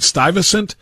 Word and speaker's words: Stuyvesant: but is it Stuyvesant, the elite school in New York City? – That's right.